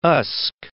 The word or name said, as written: Usk